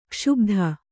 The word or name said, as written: ಕ್ಷೋಭಿತ